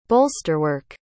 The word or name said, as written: bolsterwork